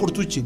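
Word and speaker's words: Contursi: Se pronuncia Cortucci.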